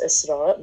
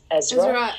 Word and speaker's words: Isra: I just wanted to say that, like, obviously with mine, like, it's, like, in Arabic it's pronounced Isra, but, like, when it's, like, spoken in English, it comes out as Ezra,